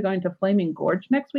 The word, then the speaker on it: Flaming Gorge: Flaming Gorge. You said it. Flaming you said Gorge. It right.